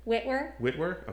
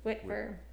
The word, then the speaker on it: Wittwer: Wittwer. – For some reason, I thought it was Witter. – Witter. Yeah, a lot of people say Witter.